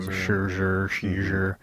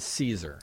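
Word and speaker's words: Szczur: And the pronunciation is Caesar. – It's Caesar. – Like, all hail Caesar.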